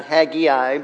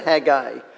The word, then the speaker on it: Haggai: and that is how you are to pronounce his name, not Haggai, but Haggai. Though he is mentioned in Ezra chapter 5,